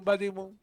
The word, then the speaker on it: Buddy Moon: Buddy Moon, ¿está bien dicho? – ¿Cómo? – Buddy, Moon, Buddy Moon, Buddy Moon, Buddy Moon. – Como Buddy de amigo. Buddy, Buddy, ¿cómo se escribe? – Ah, como el amigo, sí, el amigo de la luna. – ¿Cómo se dice? – Buddy. – Buddy Moon, ahí está.